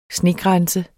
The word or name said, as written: snegrænse